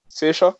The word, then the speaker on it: Saoirse: I think I said on the our preview one that it's between her and Saoirse. Is that how you pronounce it? – Yeah.